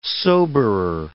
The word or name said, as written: soberer